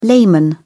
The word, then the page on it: layman: British English